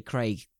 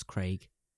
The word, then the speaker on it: Craig: It's Craigslist, because we say Craig the correct manner. – Yes. – Because we're British. – Yes, yes. – The Americans pronounce Craig, Craig.